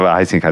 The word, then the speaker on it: Huizinga: Care, Heisinga, deci de acum aproape 100 de ani. Pentru cei care nu sunt obișnuiți cu pronunția aceasta olandeză e Huizinga în mm. uh, scris.